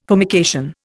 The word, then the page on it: formication: Indian